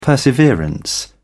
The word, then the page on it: perseverance: British English